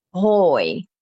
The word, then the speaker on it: hooi: And that sounds a lot like hey as well, which is hoi. Hoi. So het konijn eet hoi in zijn kooi. So think cage, same sound with a K. But then it rhymes with hoi, hoi and kooi.